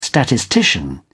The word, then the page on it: statistician: British English